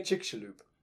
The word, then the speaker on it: Chicxulub: The famous one being, of course, 65 million years ago, the Chicxulub, if I've said that correctly, I can never remember. How do you say it again? – Chicxulub. – There we go.